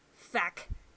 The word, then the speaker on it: feck: But you gotta say it like feck. Like like it's gotta be like percussive.